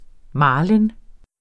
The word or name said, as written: marlin